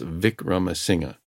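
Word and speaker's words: Wickramasinghe: I phoned my friend Professor Chandra Vikramasinghe, that's a Sri Lankan surname beginning with W, but they pronounce it like a V, so it's Vikramasinghe,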